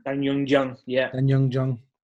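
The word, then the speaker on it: Da-un Jung: Dayun Jung, is that how they pronounce their names? Is that the name? – Dan Young Jung.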